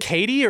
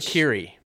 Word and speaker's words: Kiri: And then at the same moment, in the other little chamber, you've got Kiri is coming to rescue Neytiri. – Is it Katie or Kiri? – Kiri.